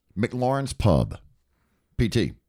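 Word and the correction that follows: MacLaren's: It's How I Met Your Mother, and you're pronouncing it incorrectly. It's McLaren's.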